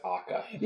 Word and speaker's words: ACCA: Like, even, I don't know why he keeps saying ACCA. I guess that's just because of the way it's written, because I looked up the Japanese title and they just pronounce it AKA.